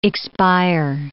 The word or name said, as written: expire